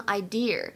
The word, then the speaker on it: idea: 还有就是这个 idea，idea，它末尾呢千万不要卷舌头，不能读成 idea，一定要注意。